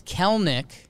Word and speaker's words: Kelenic: Jared with 2 R's, J-A-R-R-E-D. And then it's Kelnick, but it's spelled K-E-L-E-N-I-C. It looks like Kelenic. But you don't pronounce the second E.